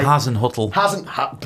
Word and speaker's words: Hasenhüttl: Hasenhüttl. – Brilliant. There we go. Proper commentator's pronunciation.